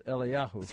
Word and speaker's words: Eliyahu: Well, there is no J. In English, it's Elijah, but in Hebrew, it's Eliyahu.